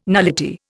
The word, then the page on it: nullity: Indian